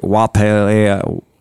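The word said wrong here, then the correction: Hualapai: Native American Reservation. – Look it up. – I'm sorry, guys. I fucking love you, but I fucked your name up. Hulapai.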